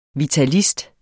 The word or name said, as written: vitalist